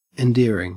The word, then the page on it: endearing: Australian English